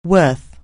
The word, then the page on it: worth: anglais